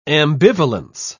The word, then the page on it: ambivalence: British English